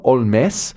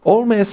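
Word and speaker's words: Holmès: Coming up soon, Augusta Olmes. Olmes spelled H-O-L-M-E-S. It is the Irish name Holmes, but transposed to France and Francified in its pronunciation.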